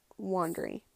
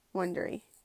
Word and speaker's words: Wondery: They pronounce it Wondery, and it's Wondery. Because Wondery doesn't have a U in it, but it has an... It's spelled W-O-N-D, if I'm not mistaken. And then R, Y, Wondery. It's kind of like you're saying Loundry. Like Brian Loundry.